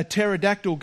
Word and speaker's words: pterodactyl: Why can't you hear a pterodactyl go to the bathroom? Because the P is silent.